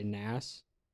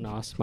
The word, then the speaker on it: Nas: So it's by Nas. Not Nos, my bad. Wow, I really butchered that.